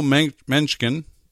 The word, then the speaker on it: Mencken: Mencken, M-E-N-C-K-E-N, Mencken is how I pronounce it.